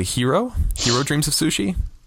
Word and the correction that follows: Jiro: I think it's Jiro. – Is it Jiro? – Wait, you watched it? Did they not say his name? – I don't remember, to be honest. We'll just say Hero. But it's spelled Jiro, J-I-R-O.